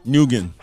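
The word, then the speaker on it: Nguyen: Number 5, N-G-U-Y-E-N. – Nugan.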